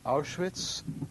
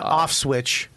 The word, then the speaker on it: Auschwitz: Auschwitz. – Auschwitz. Yeah. Auschwitz. – Not Auschwitz. – Yeah. – Not off switch. – What did you say, Auschwitz? – Well, you said Auschwitz.